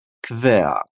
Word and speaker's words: quer: É como se nós falássemos K mais V do português com som de E e o A. Quer.